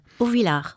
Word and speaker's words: Auvillar: Like the other fine restaurant in Auvillar, how do you say this thing? Auvillar? – Auvillar.